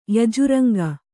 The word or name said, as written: ಯಜುರಂಗ